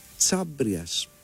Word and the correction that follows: Χαβρίας: Χάμπριας, δεν ξέρω πώς ακριβώς διαβάζεται αυτό και τι είναι ακριβώς. Είναι, Χαβρίας. Αθηναίος στρατηγός του 4ου αιώνα. – Χαβρίας. Πολύ Χαβρίας, λοιπόν.